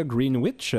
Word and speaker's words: Greenwich: Il ne faut pas dire Greenwich, donc c'est un de ces nombreux mots anglophones dans lesquels il y a un W qu'on ne devrait pas prononcer.